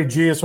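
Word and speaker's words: Geas: Freddie Gius with a soft G.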